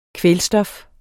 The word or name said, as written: kvælstof